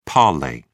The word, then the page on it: parlay: British English